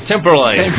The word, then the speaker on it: Temperley: Temperley.